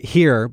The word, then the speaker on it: Hir: Here by Taylor Mack, that's at Arts West. – H-I-R. – H-I-R, pronounced here. It's a play on him and her.